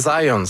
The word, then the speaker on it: Zajonc: Non, c'est Zajonc. – Oui? Ouais, j'ai-tu pas dit Zajonc? – Vous avez dit plus Zajonc, mais... – J'ai prononcé un petit peu à l'anglais. J'ai prononcé mon polonais à l'anglais.